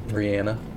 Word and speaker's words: Rihanna: I absolutely love Rihanna in every aspect. – I mean, just start saying her name correctly, but okay. – Rihanna. – Yeah, there you go.